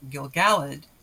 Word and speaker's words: Gil-galad: Question of why Melian has accent on her first syllable, while Gil-galad has accent on the second syllable.